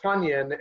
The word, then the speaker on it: Tonyan: and he finally told us – he actually had the PR staff tell us, oh, by the way, it's pronounced Tonyan.